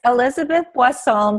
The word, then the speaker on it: Elizabeth Boisson: It was it's Elizabeth Boisson, but it was perfect when you pronounced it.